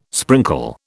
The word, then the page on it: sprinkle: British English